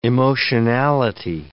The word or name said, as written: emotionality